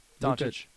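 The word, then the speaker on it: Dončić: Doncic. – It's Chich. – Luka Doncic. I think so, yeah. – Two CHs in there, even though there's not a single CH. – Not a CH. – Luka Doncic.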